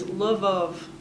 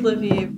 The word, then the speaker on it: Lviv: If you say it in Russian, Lviv is Lvov. Like the I turns into O in Russian, so Lviv is the Ukrainian pronunciation.